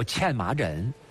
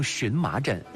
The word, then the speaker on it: 荨麻疹: 嗯，这最常见到的一个皮肤病，把它叫茜麻疹。嗯，今天大家也把它读成荨麻疹。